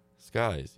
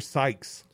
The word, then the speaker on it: Sykes: Skies, yeah. He's 28 and a rookie. And he started at guard because Malcolm Brogdon plays a game as he plays. Kiefer Sykes. – Sykes, yeah. I think it's, Skikes. – Sykes. S-Y-K-E-S.